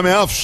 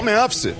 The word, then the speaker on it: Memphis: Bom, não é Memphis, né? A pronúncia correta que o empresário ali me falou aí, os holandeses falam Memphis.